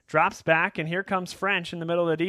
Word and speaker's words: Ffrench: But there's a gentleman named Maurice Ffrench, and yes, that's spelled French. Ffrench.